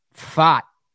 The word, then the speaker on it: Pfaadt: Pfaadt is how you spell this guy's name. Fought is the pronunciation there.